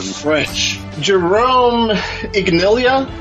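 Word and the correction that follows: Iginla: Aginla. – What? – Aginla. – Aginla?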